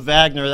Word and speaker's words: Wagner: His name is pronounced Wagner Decisse, and we here in the United States might say Wagner, but I'm going to call him Wagner. That's the way they say it down there.